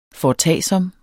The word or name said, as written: FORETAGSOMMERE